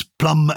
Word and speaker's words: plumb: And there's no connection with the fruit at all, P-L-U-M. – None at all. – On its own. – No, none at all. – So it's plumb as from plumbere. – Silent B. And you'll remember that it was probably Renaissance scribes translating from Latin into English who saw the word plumber and thought, well, we have to show off its Latin heritage. So they inserted a B, and we've never pronounced it, of course, but they wanted to show that it goes back to plumbum, because they wanted to, you know, keep the integrity and purity and classical history of our language.